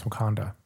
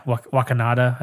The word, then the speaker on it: Wakanda: I mean, Bruce Banner doesn't even know how to pronounce Wakanda. – Yeah, Wakanada, I think he says.